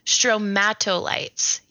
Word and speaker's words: stromatolites: Stromatolites.